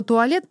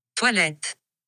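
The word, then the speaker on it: toilette: Слово «туалет» происходит от французского «туалет». Мне понадобилась помощь моей ассистентки, чтобы не допустить ошибки в произношении.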